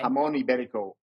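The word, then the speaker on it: Jamón ibérico: Jamon Iberico would be the Spanish pronunciation.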